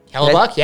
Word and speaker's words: Hellebuyck: Connor Hellebuyck. – Hellebuyck, yeah.